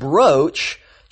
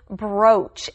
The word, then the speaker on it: brooch: Now, I said brooch, Chelsea, even though that word looks like it's pronounced brooch. – That's right. You see the two O's there, and a lot of times that makes the oo sound. But in this case, it is brooch.